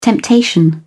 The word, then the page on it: temptation: British English